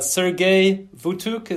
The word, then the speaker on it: Sergej Vutuc: Okay, then I have a question from Sergei Vutuk.